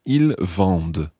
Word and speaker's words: vendent: Vous vendez, V-E-N-D-E-Z. And the il, elle plural form, ils vendent, V-E-N-D-E-N-T. And remember that E-N-T at the end is silent. Ils vendent.